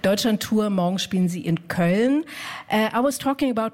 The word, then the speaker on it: Köln: Köln? Yeah. Köln.